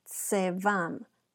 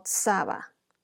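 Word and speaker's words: tzeva'am: Em, esta palabra ejército, ornamento, en la traducción hebrea, la palabra ejército, ornamento, eh, proviene de, la palabra que se pronuncia tsevam o Tzaba. La pronunciación se deletrea T-Z-E-V-A apóstrofe A-M.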